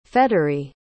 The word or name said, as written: faitery